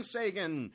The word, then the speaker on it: Sagan: That was brought to you by Brian Sagan. Rhymes with Menachem Begin.